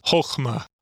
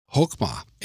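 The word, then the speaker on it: Chokmah (חָכְמָה): Chokmah. – Chokmah.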